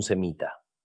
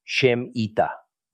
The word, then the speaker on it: semita: La palabra debería ser Shemita, no Semita.